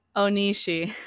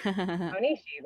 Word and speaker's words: Onishi: Wait, did I say his last name right? I always want to make sure I say it right. – Onishi. – Onishi, there we go.